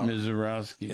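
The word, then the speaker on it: Misiorowski: I say his name, Mezalewski. – Yeah. – Threw 10 pitches over 103 miles an hour. – Mizorowski. – Yeah, you say his name? Mizor.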